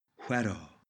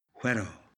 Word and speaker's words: huero: Bitter is huero, huero. Notice the h sound at the beginning, h, as in wheel, work.